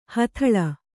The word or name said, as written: ಹುದಲು